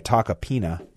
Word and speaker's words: Tacopina: a guy named Joseph Tacopino. I'm sorry, Tacopina, I believe is the pronunciation.